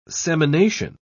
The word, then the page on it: semination: British English